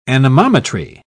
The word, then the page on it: anemometry: British English